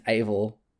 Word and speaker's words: Eivor: So Eivor, which makes me, just a side note here, the character's name's Eivor. It's pronounced Eivor, but it's E-I-V-O-R. My now-retired D&D character was called Eivor, A-V-O-R, which makes me very, very happy.